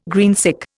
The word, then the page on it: greensick: Indian